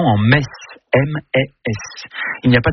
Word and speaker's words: Metz: Le meilleur moyen de mémoriser la prononciation correcte de la ville de Metz est certainement de se rendre au Stade Saint-Symphorien, où vous répéterez jusqu'à plus soif. Ici c'est Metz.